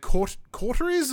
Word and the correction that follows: Coteries: It's pronounced Coteries, I'm pretty sure.